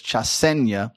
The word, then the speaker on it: Chasenya: It's just I want to say Chasenya every time, but it's Chasenya, and so you got to get that vowel structure correct in Luganda. They pronounce their vowels differently. Now, if you're an English reader and you're at home reading this, you probably thought it was Chasenya, and it's not.